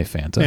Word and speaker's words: Fanta: I'm in Atlanta where it's just Fanta rhymes with that old chimney guy at Christmas. Yeah, we say Fanta. – Fanta. We say Fanta. – Santa with an F, though.